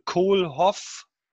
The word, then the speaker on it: Kohlhoff: Und Kohlhoff, wird so richtig ausgesprochen, hat geschrieben, Vertrauen und messbare Verbesserung ist eine gute Kombi.